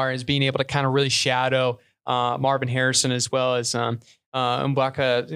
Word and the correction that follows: Emeka Egbuka: or however you pronounce his name. I keep literally messing it up every time. Mbuka or Emeka Egbuka. – Emeka Egbuka. – Oh, yeah, that's how you make it.